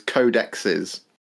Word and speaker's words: codexes: The official Warhammer way of pronouncing multiple plural codex is codexes.